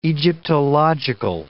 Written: Egyptological

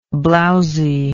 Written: blowsily

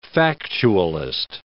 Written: factualist

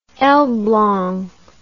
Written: elblg

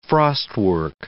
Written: frostwork

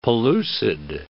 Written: pellucid

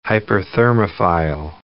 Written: hyperthermophile

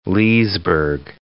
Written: Leesburg

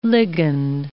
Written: ligand

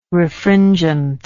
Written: refringent

